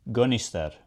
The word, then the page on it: Gunnister: Shaetlan